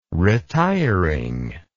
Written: retiring

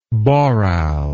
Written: boral

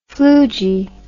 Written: flugie

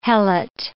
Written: helot

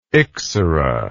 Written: ixora

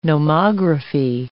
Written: nomography

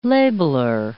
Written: labeler

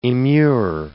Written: immure

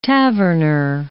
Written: taverner

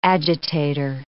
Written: agitator

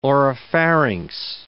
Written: oropharynx

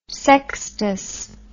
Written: Sextus

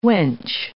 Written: wench

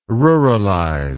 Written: ruralize

